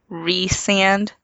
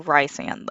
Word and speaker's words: Rhysand: Resand. I always want to say Rhysand, though.